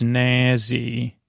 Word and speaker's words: Nazzy: And this octopus's name was Nazzy. Nazzy, not Nancy. Nazzy. Can't you say it like Nancy, but it's not Nancy. It's Nazzy.